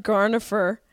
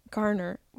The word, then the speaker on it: Garner: Jen Garnifer. Sounds like an optometrist from New England. Jen Garnifer. Ben Affleck and Jennifer Garner. Garner.